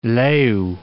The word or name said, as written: leu